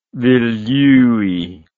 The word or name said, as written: vilyui